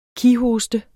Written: kighoste

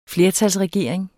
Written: flertalsregering

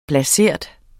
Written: blasert

